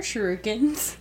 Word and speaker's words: shuriken: What are shurikens? – Shuriken, ninja throwing stars.